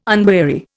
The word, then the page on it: unweary: Indian